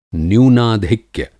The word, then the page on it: न्यूनाधिक्य: Hindi